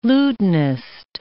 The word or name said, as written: lutenist